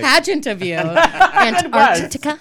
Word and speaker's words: Antarctica: So it's -22 in Antarctica. and it's negative 18. – You better just pronounce every letter in that fucking word. Antarctica.